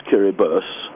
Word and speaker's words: Kiribati: It's part of the Republic of Kiribati, properly pronounced Kiribus.